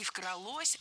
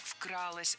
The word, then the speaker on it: вкралось: Не «вкралось», а «вкралось». Ударным должен быть первый слог.